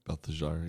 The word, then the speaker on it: Belteshazzar: Belteshazzar, yeah.